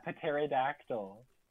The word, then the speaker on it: pterodactyl: pterodactyl. – And then like. – Speaking of a silent P, we have P ranked next.